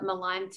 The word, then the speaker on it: Malan: My connection to Malan syndrome is that I am one of the co-founders, but I myself also have a child who is affected or diagnosed with Malan.